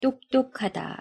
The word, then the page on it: 똑똑하다: Korean